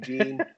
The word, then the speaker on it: Jean: but everybody called him Gene. So we're sticking with Gene. My suspicion is that his name is Jean Baptiste because he's from Haiti. Yep. It's spelled J-E-A-N in French. That's Jean.